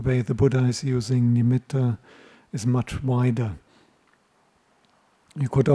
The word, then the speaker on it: nimitta: Nowadays when people talk about a nimitta, often they pronounce it as nimitta, but the Pali has a double T, nimitta.